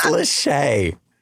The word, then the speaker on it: Lachey: Is it Latchkey or is it Lahey? I'm looking at this name going, how do you pronounce this name? – It's Lachey.